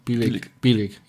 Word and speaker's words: Bielig: Norman Bielig, richtig? Bielig? – Genau. – Bielig. Ich habe es richtig ausgesprochen.